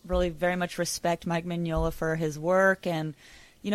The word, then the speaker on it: Mignola: Mike Mignola, phonetically spelled M-I-N-Y-O-L-A. Thanks Reddit user Middleway, Craig McKnight, and Mike Corley for giving us this info.